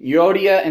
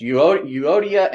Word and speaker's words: Euodia: But in the fourth chapter, there are two women mentioned, Euodia and Syntyche. It's weird spelling, so that's my pronunciation of such. Euodia and Syntyche.